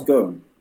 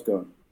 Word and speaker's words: Ghosn: Carlos Ghosn. Silent S, Carlos Ghosn. – As in gone.